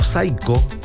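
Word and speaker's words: Psycho: Vamos a escuchar al grupo Saiko. Este no es el grupo Saiko chileno, es el grupo Saiko argentino, ¿ya? Se escribe Psicho, así, Psicho, con P al principio, Psicho.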